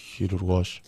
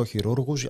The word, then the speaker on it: χειρουργός: Είναι το σωστό, είναι το χειρουργός. – Γενικά θεωρείται ότι στην παραλίγουσα τονίζονται τα κακόσημα, όπως κακούργος, πανούργος. Εγώ επειδή δεν συμπαθώ τους χειρουργούς, τους λέω χειρούργους ακριβώς για τον ίδιο λόγο.